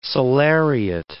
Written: salariat

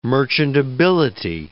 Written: merchantability

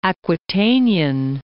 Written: Aquitanian

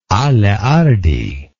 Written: aleardi

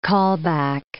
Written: callback

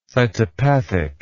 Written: cytopathic

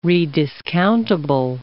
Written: rediscountable

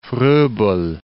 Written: Frobel

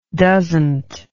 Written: doesnt

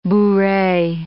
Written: bourree

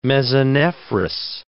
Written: mesonephros

